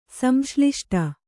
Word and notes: ಸಂಶ್ಲಿಷ್ಟ: samśliṣṭa

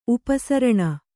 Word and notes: ಉಪಸರಣ: upa saraṇa

